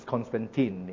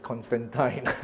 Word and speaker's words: Constantine: Or some people pronounce as Constantine. In this part of the world, it pronounces as Constantine.